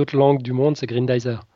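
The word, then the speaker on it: Grendizer: Ouais, Grindizer, c'est le nom original de Goldorak.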